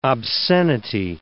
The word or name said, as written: obscenity